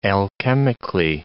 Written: alchemically

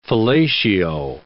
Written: fellatio

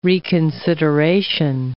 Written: reconsideration

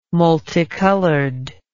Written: multicolored

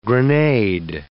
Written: grenade

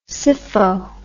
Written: sypho

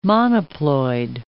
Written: monoploid

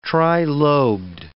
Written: trilobed

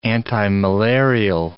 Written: antimalarial

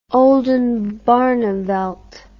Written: Oldenbarneveldt

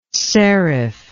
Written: ceriph

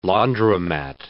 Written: Laundromat

